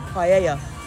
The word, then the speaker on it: paella: and I am confronted by massive plates of paella.